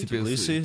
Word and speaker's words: Tbilisi: Tbilisi, Tbilisi, Tbilisi.